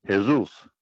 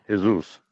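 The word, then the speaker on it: Jesús: Along with the letter J, Jesus in Mexico is pronounced Jesus with the H, yeah. – I believe. It's a different, totally different pronunciation. Jesus.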